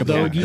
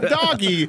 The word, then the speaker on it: dogie: Not doggy. Dogie. – I said dogie. Right. Yeah. Thank you. – You pronounced it correctly.